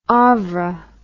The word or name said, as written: OVRA